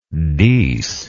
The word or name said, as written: dees